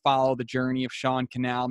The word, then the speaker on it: Cannell: Sean Cannell everywhere on social, S-E-A-N, Sean, and last name Cannell, rhymes with YouTube channel, spelled C-A-N-N-E-L-L.